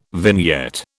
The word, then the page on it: vignette: British English